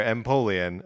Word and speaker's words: Empoleon: Yeah, I'm guessing it's like Napoleon, so like Empoleon. – Sure, Empoleon.